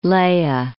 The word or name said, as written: Leie